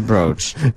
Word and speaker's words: brooch: A brooch or brooch. No one says that. It's a brooch. It's spelled B-R-O-O-C-H.